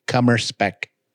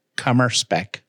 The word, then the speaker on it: Kummerspeck: Cummer spec. – What is it? – Cummer spec. – Could you spell it, please? – K-U-M-M-E-R-S-P-E-C-K. Cummer spec.